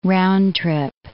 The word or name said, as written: round-trip